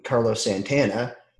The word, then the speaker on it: Carlos Santana: I think the next one, Carlos Santana, he was the 45th.